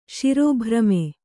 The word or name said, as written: ಶಿರೋಭ್ರಮೆ